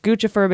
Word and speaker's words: Guccifer: Then there's Guccifer 2.0, which is pronounced Guccifer because it's a combination of Gucci and Lucifer.